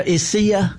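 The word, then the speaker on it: Isaias: Isaiah. Is that how you say it? Isaiah? – Isai. – Isai.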